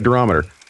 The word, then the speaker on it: durometer: Durometer 88. – Oh, okay, cool. – Durometer. – Yeah, you said it. You said it right. Durometer.